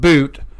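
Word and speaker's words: Bout: So anyways, Brittany Griner and Victor Boot, it's pronounced Boot,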